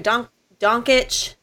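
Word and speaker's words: Dončić: Behind him is Luka Doncic.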